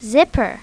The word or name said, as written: zipper